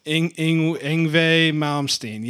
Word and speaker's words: Yngwie Malmsteen: And then, you know, he's got the Scorpions and instrumental guitarists like Yngwie Malmsteen. I'm saying that probably not quite right. – Yngwie Malmsteen. – Yngwie Malmsteen, yes.